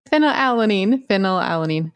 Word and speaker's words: phenylalanine: How do you say the phenylamine? – Phenylalanine, lysine, valine, threonine, and tryptophan.